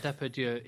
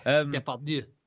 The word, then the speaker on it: Depardieu: Well, I'm always confused and suspicious because I've never seen him and Gerard Depardieu in a room at the same time. – Nice pronunciation. – Depardieu.